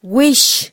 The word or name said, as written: wish